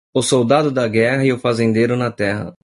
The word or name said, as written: soldado